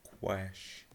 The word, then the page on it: quash: American English